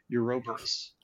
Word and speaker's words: Ouroboros: So that's Ouroboros, which I just learned today how to pronounce it. Ouroboros. Ouroboros. Ouroboros. That's it. Ouroboros.